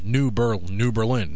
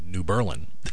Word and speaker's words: New Berlin: And people that aren't from here and they see New Berlin, it's New Berlin, not New Berlin.